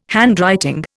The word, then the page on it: handwriting: Indian